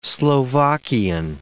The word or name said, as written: Slovakian